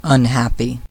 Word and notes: unhappy: American English

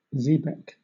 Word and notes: xebec: British English

